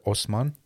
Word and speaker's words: Haussmann: And or maybe in French it's called Haussmann.